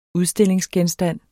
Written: udstillingsgenstand

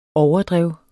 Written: overdrev

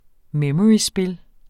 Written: memoryspil

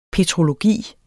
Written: petrologi

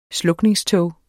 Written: slukningstog

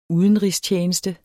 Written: Udenrigstjeneste

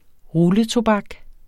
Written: rulletobak

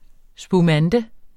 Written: spumante

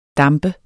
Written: damp

